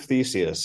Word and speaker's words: Theseus: Yeah, ship of Theseus.